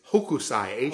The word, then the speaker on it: Hokusai: I butchered it. It's Hokusai. Hokusai.